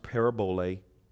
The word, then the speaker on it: parabole: It's P-A-R-A-B-O-L-E, and the E is pronounced A, parabole.